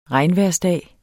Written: regnvejrsdag

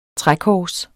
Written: trækors

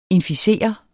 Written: inficere

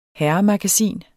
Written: herremagasin

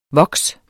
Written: voks

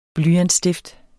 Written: blyantsstift

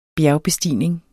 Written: bjergbestigning

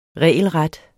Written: regelret

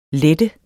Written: lette

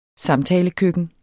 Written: samtalekøkken

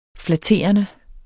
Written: flatterende

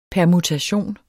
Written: permutation

